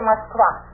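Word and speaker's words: Moskva (Москва): Now, tell us about how do you pronounce it? Moscow? – No, we say Moskva.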